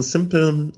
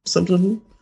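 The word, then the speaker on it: Simplon: ja, ich hatte auch direkt so einen simple, wie, wie spricht man den aus? Simple Rahmen? Ja, ne? – Simplen, die Firma.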